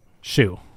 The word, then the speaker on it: Hsu: It's HSU. I don't know how to say it. – Shu. – Yeah. – Shu? Okay.